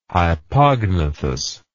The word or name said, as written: hypognathous